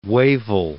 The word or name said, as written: Wavell